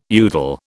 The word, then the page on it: udal: British English